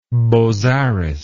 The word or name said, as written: bozzaris